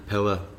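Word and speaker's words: Peder: And Pillar Black.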